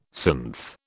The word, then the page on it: synth: British English